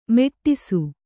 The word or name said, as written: ಮೆಟ್ಟಿಸು